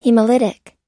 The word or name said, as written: hemolytic